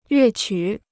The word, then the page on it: 乐曲: yuè qǔ